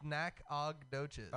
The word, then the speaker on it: Nacogdoches: N- It's Nacogdoches. Oh, there's no U. Almost douches.